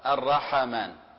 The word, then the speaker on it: الرحمن: كذلك بعضنا يخطئ في نطق كلمة الرحمن في تحريك الحاء بالفتح، الرحمن، وهي ساكنة، الحاء ساكنة وفيها همس.